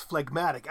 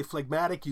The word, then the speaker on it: phlegmatic: And when you split into two syllables that G is suddenly pronounced. So it's phlegm, but then it's phlegmatic.